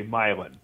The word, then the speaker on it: Milan: Actually, in Illinois, we pronounce it in an odd way. We say Milan. – Milan, okay. M-I-L-N. I said Milan as in Spain. – As in Italy.